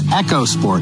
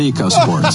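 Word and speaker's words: EcoSport: It's Echo Sport, not Eco Sport.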